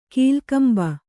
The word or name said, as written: ಕೀಲ್ಕಂಬ